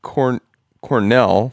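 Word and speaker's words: Kornél: We're going to go with Cornell, first of all. That's not a... – Well, it's got the apostrophe over the E, though.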